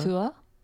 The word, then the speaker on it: Tuatha: The Tua, how did you say that, the first word? – Tua. – Yeah. T-U-A-T-H-A, you pronounce it?